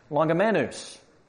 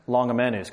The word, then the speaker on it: Longimanus: So I consulted the expert on how to pronounce Longimanus, because that's actually a Latin word. It stands for long-handed, and he said probably Longimanus.